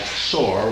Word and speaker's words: Sore: And that looks like sore when we write it. But it is really two syllables, as the Baya say it, sore.